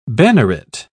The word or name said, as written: banneret